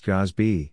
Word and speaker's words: GASB: Driven in part by new Government Accounting Standards Board, GASB, pronounced GASB,